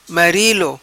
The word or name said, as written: Marilo